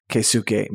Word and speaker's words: Keisuke: and he is called Keisuke Miyagi. It's, you want to spell it? Because you have it right there, I believe. – Sure. That would be K-E-I-S-U-K-E.